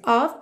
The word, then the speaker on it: of: De novo, esse of, OF com som de OV.